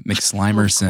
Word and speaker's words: McSlimerson: Thank you for pronouncing my last name correctly, McSlimerson. – Of course, it's basic respect. – I thought it was McSlimson. – That's the Polish pronunciation.